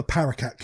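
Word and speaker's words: paraquat: Or the paracat killer, depending on... – Is that how you're pronouncing it? – Paracat. I think it's spelled like Q-U, like French. – It's Q-U-A-T, yeah.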